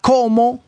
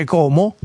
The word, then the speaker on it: Como: Bravo, con la O bravo, aperta. Anch'io. No, Como con la O chiusa, io l'ho sentito con la O chiusa.